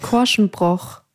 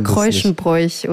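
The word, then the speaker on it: Korschenbroich: Wie hat er es jetzt nochmal gesagt? Korschenbroch. Oh. So ist, glaube ich, richtig. Und ich habe mal Kreuschenbräuch oder keine Ahnung, was ich gesagt habe.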